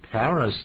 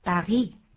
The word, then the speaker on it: Paris: 啊，好像你这个发音有点怪，你是讲 Paris，这是英文的发音。可是我在巴黎那边，他们不叫 Paris，他们念 Paris。